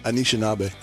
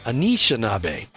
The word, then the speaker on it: Anishinaabe: How did you say it? You said it different. – I say it with an Oklahoma accent. Anishinaabe. – Anishinaabe.